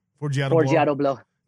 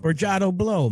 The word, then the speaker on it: Forgiato Blow: Forgiato Blow. – Jeez, why do I have to mess it up? – Forgiato Blow, man.